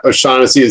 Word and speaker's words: O'Shaughnessy: And then a 2010, tell me if I'm saying this correctly, a 2010 Oshag Hennessy, Hell Mountain Cabernet Sauvignon for $95. – No, you know, it's funny. I was gonna mention the O'Shaughnessy wines because Oshag Hennessy.